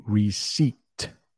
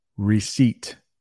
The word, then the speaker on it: receipt: One thing that you might get if you go into a store and buy something is a receipt. That's how you pronounce that word. Receipt. I know. It has a P in it. Doesn't make any sense.